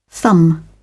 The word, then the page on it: thumb: British English